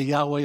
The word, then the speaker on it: Yahweh: So it's the great name of God pronounced Yahweh.